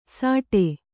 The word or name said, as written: ಸಾಟೆ